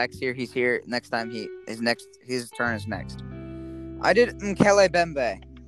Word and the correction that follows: Mokele Mbembe: It's pronounced Mokele Mbembe.